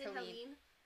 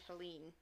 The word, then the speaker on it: Helene: Aunt Helen, or Aunt Helene, however you want to pronounce it. – I think it's Helene. – Sorry. – I think it's spelled Helen, but like in the movie they keep calling her Aunt Helene. – Then she's Helene.